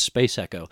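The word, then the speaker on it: SPC ECO: It's SPC space ECO, and but it's pronounced Space Echo.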